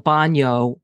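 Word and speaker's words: banho: In Spanish, it's B-A-N with a tilde, B-A-N-O. Yeah. In Portuguese, it's B-A-N-H-O. And that's how you make the ñ sound.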